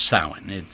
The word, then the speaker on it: Samhain: Spelled Samhain, but it's pronounced Samhain. It's Celtic or Gaelic or something, so the letters don't matter. It's Samhain.